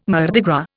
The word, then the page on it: Mardi Gras: Indian